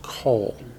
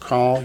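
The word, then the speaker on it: kol (כֹּל): Notice the word pair form is spelled with a kamet, but it's still pronounced kol. Sometimes you'll hear it pronounced call. You'll hear it both ways. But it should be kol.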